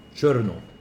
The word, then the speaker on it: Cherno: But the Russians, they piloted, I don't know how to pronounce this, Cherno Alpha? – Cherno. Like Chernobyl.